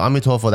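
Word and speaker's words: Amitofo (阿彌陀佛): So Amitofo, that's the Chinese pronunciation of like Amitabha, which is like Sanskrit, you know?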